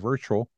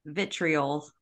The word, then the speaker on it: vitriol: virtual on the platform. Is that how you say it? Virtual? – Vitriol.